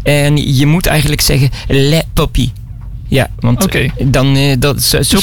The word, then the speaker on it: Les Papiers: De S spreek je niet uit op dat moment. Okay. Les Papiers. Ah. Okay. Les Papiers moet je eigenlijk zeggen. Want de S die spreken.